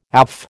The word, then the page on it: AF: American English